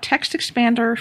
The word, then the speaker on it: Text Expander: And I want to talk about Text Expander for the iPad.